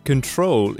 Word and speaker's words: control: Now here, control is different from the other two because the stress is on the second syllable, the main stress, yeah? While in the other two, it's on the first syllable.